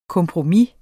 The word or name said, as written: kompromis